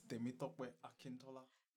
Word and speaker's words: Temitope Akintola: Okay, so it's Temitope Akintola.